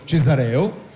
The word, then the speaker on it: Cesareo: Fra Marco Moroni e il direttore della comunicazione Fra Giulio Cesareo, questa volta l'ho detto bene con l'accento giusto, il direttore per la comunicazione del Sacro Convento.